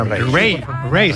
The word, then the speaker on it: Raye: Ray, se escribe Raye, con Raye, ya Raye.